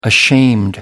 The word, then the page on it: ashamed: American English